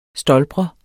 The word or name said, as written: stolpre